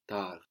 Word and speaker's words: tard: Late in French is tard. – Tard. – Tard. And this is spelled T-A-R-D. So the final consonant in French, as we have been seeing, is rarely pronounced T-A-R-D, but we say tard.